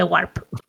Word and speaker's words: Warp: No sé si se habrá entendido el, el pronunciation, pero eso, The Warp. – W-A-R-P.